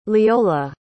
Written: leola

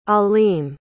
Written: alim

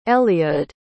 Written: eliud

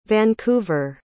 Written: vancouver

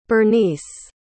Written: Bernyce

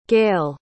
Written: Gail

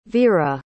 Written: vera

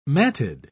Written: matted

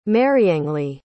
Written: mariangely

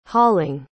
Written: hauling

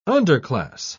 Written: underclass

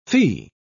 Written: fee

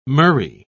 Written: murrey

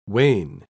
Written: wane

